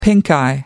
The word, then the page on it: pinkeye: British English